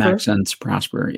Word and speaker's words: Maxence Prosper: What do you think about Olivier Max? I don't know if I'm saying his name correctly. Prosper? – Maxence Prosper. Yeah.